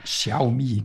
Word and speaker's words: Xiaomi: Ähm, man befindet sich hier in einem Wettbewerbmarkt und, und Apple ist hier lediglich auf Platz drei hinter Samsung und hinter Xiaomi werden die, glaube ich, ausgesprochen. – Ich Xiaomi, genau. – Ja. Xiaomi, genau.